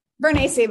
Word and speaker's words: Vernaci: Vernaci, but close.